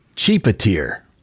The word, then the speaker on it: Tjipetir: The word is spelled T-J-I-P-E-T-I-R. – T-J-I. – P-E-T-I-R. And they pronounce it as cheapeteer.